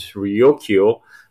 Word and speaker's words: Ryukyu: Because, in China, the Liu Island, or aka the Japanese pronunciation is Ryukyu, the Okinawa island chain has had long historical contact with the Chinese mainland.